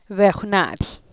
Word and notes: bejw na’dy: Teotitlán del Valle Zapotec

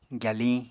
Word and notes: gyaliñ: apatani

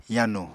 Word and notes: yanu: Apatani

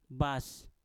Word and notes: bas: mpur

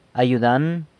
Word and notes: ayudan: San Lucas Quiaviní

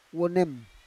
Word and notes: wonem: Roon

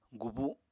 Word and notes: gubu: apatani

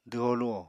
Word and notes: dwho-luho: apatani

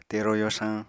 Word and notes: tero yorsañ: Apatani

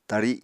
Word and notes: tari: Apatani

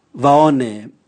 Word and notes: vaone: Raga

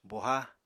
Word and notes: boha: Apatani

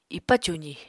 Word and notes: ipa cuzi: apatani

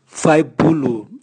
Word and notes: vwaibulu: Raga

